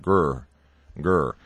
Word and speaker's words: Greer: Just like the city of Greer is not pronounced Greer, it's pronounced Gurr, Gurr.